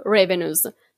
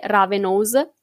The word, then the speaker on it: Ravenous: Assolutamente, allora, in inglese si leggerebbe proprio revenues, mentre la pronuncia scritta come è scritto così è ravenous, fashion, come moda, podcast.